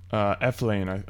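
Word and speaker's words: Elflein: He's another first-round NFL surefire guy, and add in Pat Elflin from Ohio State, the guard. – Eflane, I believe it's pronounced.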